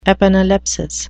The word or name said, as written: epanalepsis